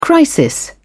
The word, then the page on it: crisis: British English